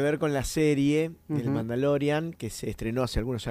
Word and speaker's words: Mandalorian: Porque, como te decía, ya está disponible la preventa para el estreno de Mandalorian. ¿Lo digo bien? – Hasta ahora sí. – Mandalorian.